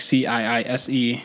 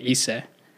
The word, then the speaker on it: Ciise: So my last name is like Ciise. Mm-hmm. So you just say Ciise. – So for somebody from like Michigan, like myself, like the way that you would coach me to just say your last name would just be Ciise. – Ciise. – Don't try the intonation.